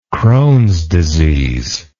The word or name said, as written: crohns disease